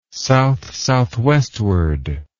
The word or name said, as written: south-southwestward